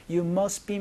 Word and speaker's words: must be: You must be 이런 식으로 STB 사이에 있는 T 소리를 살짝 날려서 You must be Mason 이렇게 여러분이 얘기를 한다라는 것도 같이 기억해 주시면 좋겠습니다.